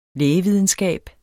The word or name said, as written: lægevidenskab